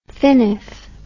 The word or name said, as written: finif